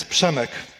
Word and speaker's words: Przemek: First of all, I need to teach you how to say my name. My name is Przemek. Can you? That's not good. So the first sound of my name is like opening a can of Coke. Psh.